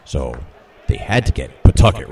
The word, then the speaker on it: Pawtucket: Okay, these out-of-staters always seem to pronounce the W, Pawtucket. True Rhode Islanders lose the W. They just go, Pawtucket.